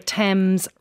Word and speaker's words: Thames: Please stop pronouncing the H in Thomas, Thailand and the Thames River.